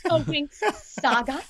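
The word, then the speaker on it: The Winx Saga: And that's because, you know, obviously you have Fate, A Wink Saga coming out. Am I saying that right? Is that right? A Wink Saga? – Yes, you just said it. Fate, A Wink Saga.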